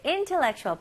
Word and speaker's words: intellectual: Okay, intellectual. 발음 연습부터 해볼게요. 자, 비슷하게 이제 intel로 시작하는데요. 여기 잘 들어보세요. Intellectual. Intellectual. 네, 여기는 강세 앞부분에 살짝 있고, 그래서 in, 그 다음에 tellect. 여기 leg에도 강세가 들어갑니다.